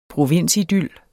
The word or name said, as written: provinsidyl